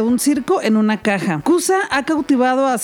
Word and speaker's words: Kooza: Cusa, que se escribe K-O-O-Z-A, está inspirado en la palabra sánscrita cosa, que significa caja, cofre o tesoro.